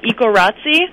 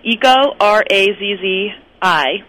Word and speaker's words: Ecorazzi: My next site is called, this is another weird spelling, weird pronunciation, but Ecorazzi, like paparazzi. So it's Eco, R-A-Z-Z-I.